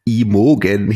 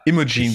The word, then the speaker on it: Imogen: Dann nehme ich von Imogen Heap. – Imogen, glaube ich, heißt es.